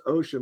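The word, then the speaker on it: Wouxun: This one is pronounced ocean, but it's W-O-U-X-U-N.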